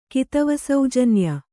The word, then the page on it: ಕಿತವಸೌಜನ್ಯ: kannada